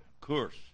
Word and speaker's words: Kursk: Kursk, that's how he'll pronounce it.